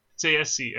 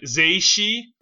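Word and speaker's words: Tsaesci: And then the interviewers apparently said Tsayesi, and he likes that more than what he originally had. Tsayesi. – So Tsayesi? – The original thing is like Zeishi, and like, he didn't like that, and then Tsayesi.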